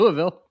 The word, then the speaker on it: Louisville: I don't know if I'm pronouncing that properly. – Absolutely. – Lee will tell us in a moment. Yeah, fantastic. Yeah. Louisville.